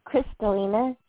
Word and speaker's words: Kristalina: Yes, it's Chris Lena. K-R-I-S-T-A. L-I-N-A, Crystalina.